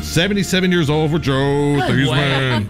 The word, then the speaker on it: Theismann: Joe Theismann, they said his name wrong. It's Theismann, but it's Theismann for the Heisman campaign at Notre Dame.